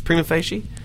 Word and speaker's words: Prima Facie: Is that how you pronounce Prima Facie? – Yeah. – Nail on the head.